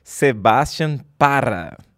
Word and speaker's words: Sebastián Parra: Estou aqui com Rodrigo Vinhas, Lucas Puerto e hoje estamos entrevistando diretamente da Colômbia, Sebastian Parra.